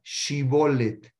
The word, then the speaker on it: Shibolet: ellos no pueden pronunciar la Shin, la, pronunciaban como Sin. Entonces le decían, dame, les decían una palabra, los probaban como, le decían, dime la palabra Shibolet.